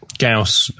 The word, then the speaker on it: Gauss: Is it Gauss? Is that how you pronounce it? Yep. Gauss came up with an idea involving 100 mirrors, which were 16 square feet each.